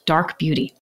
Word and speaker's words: DRK Beauty: She's an amazingly talented entrepreneur and founder of DRK Beauty, pronounced Dark Beauty.